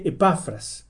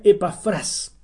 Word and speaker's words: Epafras: En español, como está escrito en la Biblia, debiera pronunciarse Epafras. Pero en el original, la tilde está al final, en la última sílaba. Entonces se pronuncia Epafras.